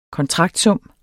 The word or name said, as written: kontraktsum